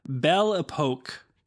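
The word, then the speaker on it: Belle Époque: Belle Epoque Paris.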